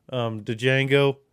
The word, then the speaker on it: Django: Senor Candy and Django, the D is silent.